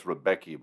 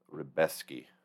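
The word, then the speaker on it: Rębecki: I know it looks like it's Rebecki, but I think you say Rebesky.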